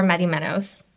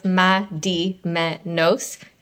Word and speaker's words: Madimenos: It's exactly how you spell it, so it's Madimenos. It's Greek, but you know.